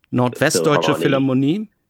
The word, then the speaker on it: Nordwestdeutsche Philharmonie: And I know I found out that he recorded a lot of recordings with the I'm going to pronounce it Norddeutschland. – Nordwestdeutsche Philharmonie. – Yes, thank you.